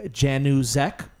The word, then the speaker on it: Januszek: Yes, his name is Matthew Januszek, and he's awesome.